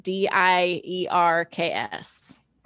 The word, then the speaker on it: Dierks: So Dirk Bentley, is that how you say it? – Yeah, it's Dierks, D I E R K S. – Oh, there's an S.